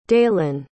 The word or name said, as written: daylen